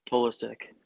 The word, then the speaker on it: Pulisic: Pulisic.